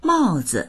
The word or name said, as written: mao4zi